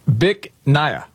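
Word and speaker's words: Bích Nga: you pronounce it Bic Naya. – Bic Naya? – Yes, but it's spelled B-I-C-H, one word. – Yeah. – Second word, N-G-A.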